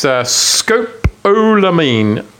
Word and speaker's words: scopolamine: William Wilcox, the scientific advisor for the police, he found traces of the calming drug scopolamine in the torso. The corpse. – That's how you say it. – Is that how you say it? – No, scopolamine. I don't know. – It's scopolamine.